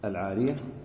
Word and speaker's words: العارية: قال رحمه الله باب العارية بتخفيف الياء العارية وبتشديدها